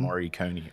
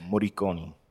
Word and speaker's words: Moriconi: Moriconi or Moriconi. – Is that the proper pronunciation? – Who knows? Probably.